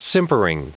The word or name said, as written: simpering